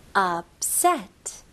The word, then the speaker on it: upset: upset. 네, 여기서는 p 다음에 바로 s가 나오니까 p 발음이 좀 약화가 돼요. 그래서 너무 p 발음 강하게 하는 것보다는 upset. 그래서 바로 입술이 붙고 나서 바로 s로 넘어가시면 돼요.